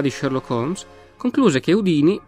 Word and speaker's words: Houdini: Cominciamo intanto col dire che il suo nome si pronunciava Houdini, con l'accento sulla prima I e non Houdini.